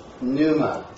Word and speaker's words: pneuma: I'm saying it pneuma, not pneuma. I'm not making a break right here.